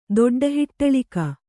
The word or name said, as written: ದೊಡ್ಡಹಿಟ್ಟಳಿಕ